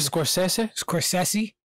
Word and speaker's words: Scorsese: Scorsese. – Yeah, there's no T. Scorsese.